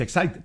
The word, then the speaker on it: Exide: Como quiera, Hormuz u Hormuz, dentro se escribe Exide, se pronuncia Exide, dentro decía el comercial, sí, de las baterías.